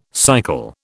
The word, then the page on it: cycle: British English